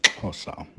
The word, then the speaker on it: Xhosa: And one of the first steps is to break the power of the Zulus along with other African people like the Xhosa. So, any better? – No. Although I do have it from a genuine South African that it's pronounced like this. – Xhosa. – So Xhosa it is.